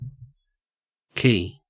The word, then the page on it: quay: Australian English